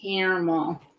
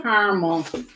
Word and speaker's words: caramel: How do you say it? – Caramel. – Caramel. – How I pronounce it. But caramel. – Caramel. – Yeah, caramel. – I guess that's our Midwestern way, yeah.